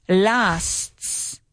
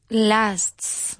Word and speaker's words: lasts: Okay, you have to add the extra S, so it's s. Lasts. Say it. – Lasts.